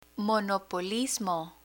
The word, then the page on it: monopolismo: Spanish